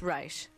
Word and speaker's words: Reich: I'm pretty sure it's Reich because even he pronounces it that way, I think.